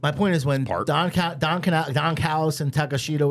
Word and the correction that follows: Takeshita: I like what they're doing with, how you say it? Takeshita, right? – Takeshita.